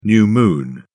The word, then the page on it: new moon: British English